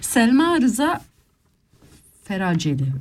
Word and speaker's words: Selma Rıza Feraceli: Selma Rıza Feraceli. Öyle okunuyor değil mi? – Tabii ki sen Feraceli.